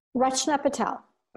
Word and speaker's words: Rachna Patel: Dr. Rachna, am I saying that right? Rachna Patel? – Rachna Patel.